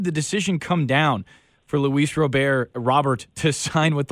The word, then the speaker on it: Luis Robert: So, but I've always heard as Luis Robert.